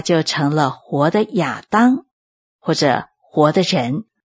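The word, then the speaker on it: 亚 当， (אדם): 的确是的，有灵的活人是我们中文圣经的翻译，在原文就是活的人，希伯来文的发音就是亚当，所以我们中文圣经就在这里加上一句说名叫亚当。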